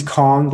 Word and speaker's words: Khong: You know, my first name is Kong, last name is Shong, first name is spelled K-H-O-N-G, last name is X as in X-ray, I-O-N-G.